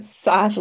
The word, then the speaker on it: Scythe: I'm like, oh, well, that's, and then halfway through the book, you're like, yes, well, Scythe keeps telling our dad that it's Scythe with a silent C.